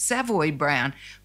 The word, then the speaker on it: Savoy: And I apologise because here in Australia, we've all always pronounced it Savoy Brown, and I hear you pronouncing it Savoy Brown.